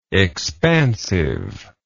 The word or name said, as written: expansiveness